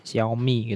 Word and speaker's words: Xiaomi: Udah bener kan ya bacanya Xiaomi gitu ya.